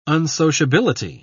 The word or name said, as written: unsociability